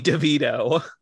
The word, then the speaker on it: DeVito: I'm going to say Danny LeVito. – Okay, first Danny of all, LeVito? You said it wrong. It's Danny DeVito.